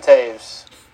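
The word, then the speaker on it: Toews: I can never pronounce his last name right. – Okay. – I'm sorry. – Is it Taves? – Yes, Jonathan Taves.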